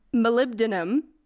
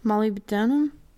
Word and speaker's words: molybdenum: guy discovered oxygen, another one I'm going to have a problem pronouncing, m- molybdenum. You're looking at me as if I could decipher that. Mm, it's like, it looks like Molly B. Denim.